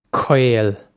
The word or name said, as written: caol